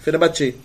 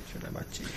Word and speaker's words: Fenerbahçe: Fenerbahçe. É assim que fala. Né? Eu conheci uma turca lá na França que eu perguntei pra ela como é se pronunciava. – É, Fenerbahçe. Fenerbahçe.